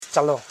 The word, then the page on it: chalon: cha.lon